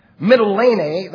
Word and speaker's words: Mytilene: Mytilene, that's how you pronounce it in Greek, which is the largest city on the island of Lesbos, which is by the sea, 30 miles away.